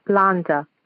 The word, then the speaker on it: Landa: In the meantime, Landa's wedding is on the same day as my baseball." I'm sorry? – Landa. L-A-N-D-A. – Okay. – Her name is Iolanda. Iolanda.